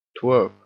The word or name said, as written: twerp